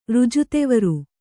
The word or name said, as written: ರಾಜದ್ವಾರ